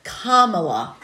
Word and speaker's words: Kamala: I'm sorry. Kamala. Calm. Okay, Kamala. Got it.